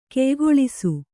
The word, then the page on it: ಗಾಯಗೊಳಿಸು: gāyagoḷisu